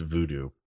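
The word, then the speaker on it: Vodou: and it's V-O-D-O-U. – Yeah. – Pronounced voodoo.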